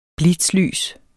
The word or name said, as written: blitzlys